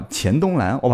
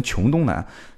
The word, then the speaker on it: 黔东南: 啊，我上期节目好像读这个，读了一个城市的名字叫黔驴技穷的黔啊，黔东南，我把它读成穷东南，我没文化啊，我没文化，没文化，真的，这个应该读澎湃是吧？